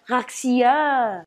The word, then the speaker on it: Garcia: La tenista Renata Sarasúa logró un histórico triunfo en el US Open al imponerse en dos sets a la francesa Caroline Garcia, que en francés se pronuncia Garcia.